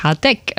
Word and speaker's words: Hardegg: Für viele fast noch geläufiger ist ja die Burg Hardegg, also Betonung auf der zweiten Silbe im Waldviertel, sozusagen der Stammsitz.